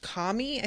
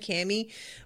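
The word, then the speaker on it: Camie: So he comes out of the water and reunites with, I think her name is pronounced Kami. I think that's the way they're saying it in the show. I keep saying Cammy, like the tank top, the lacy, top that you wear underneath a shirt.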